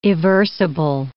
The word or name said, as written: eversible